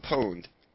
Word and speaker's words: pwned: Yeah, I'm pronouncing it right, too. – Fucking pwned. – Fucking pwned. – It's a fucking P-W-N, dude. – So what? – I know it is P. It's pwned, though.